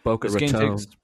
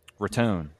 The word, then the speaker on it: Boca Raton: I think I said that right, hopefully. – It's Boca Raton. – Raton. I figured it was something like that.